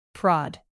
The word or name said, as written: prod